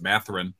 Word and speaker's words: Mathurin: When I say it, it sounds like I've got a lisp, so I always forget how to say it. But Mathurin.